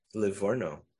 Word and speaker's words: Livorno: Livorno. That's how you pronounce it.